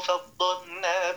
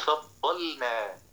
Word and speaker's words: فضلنا: تقول: تلك الرسل فضلنا، تبين اللام وتبين النون على رفق. كن رفيقا حال النطق ولا تبالغ خشية أن تقع في ثلاثة محاذير. أما المحذور الأول إدغام اللام في النون كأن تقول فضلنا، والمحذور الثاني المبالغة في السكون حتى تصير مقلقلة.